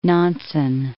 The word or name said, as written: Nansen